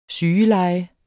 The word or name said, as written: sygeleje